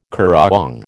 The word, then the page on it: currawong: American English